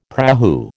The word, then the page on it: prahu: American English